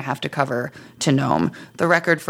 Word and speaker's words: Nome: No, it's Nome. – Are you sure? – I do know. – Okay. – It would be hilarious now that I said that if I was wrong, but I've... – Nome spelled N-O-M-E. – Yes. – Not like the small woodland fairy. – No, not like a gnome. Just like gnat is spelled N-A-T, not like a gnat.